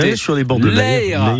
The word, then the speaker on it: Eyre: Merci beaucoup, le mot de la fin qui a très peu parlé aujourd'hui parce qu'il tient à ce qu'on prononce très bien cette rivière qui passe à côté. On dit que c'est... – L'Eyre. – L'Eyre, voilà, ça c'est l'accent. Merci beaucoup.